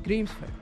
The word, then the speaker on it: Grímsvötn: He's monitoring the erupting volcano there, which, as you heard, is pronounced Grímsvötn. You almost don't even say the N at the end.